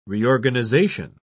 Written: reorganization

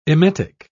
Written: emetic

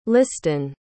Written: liston

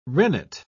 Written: rennet

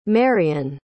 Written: marrion

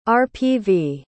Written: rpv

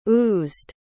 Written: oozed

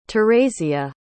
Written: theresia

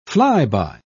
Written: flyby

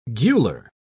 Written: gular

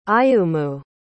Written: ayumu

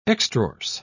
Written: extrorse

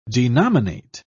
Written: denominate

